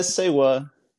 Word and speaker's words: Seiwuh: Yeah, Seiwuh. Just like Seiwuh.